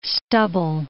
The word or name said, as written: stubble